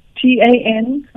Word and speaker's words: Tanija: Tanija.